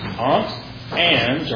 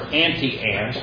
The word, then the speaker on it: Auntie Anne's: And one is Aunt Anne's or Auntie Anne's. Auntie Anne's. I hear it pronounced both ways.